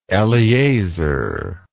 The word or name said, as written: Eleazar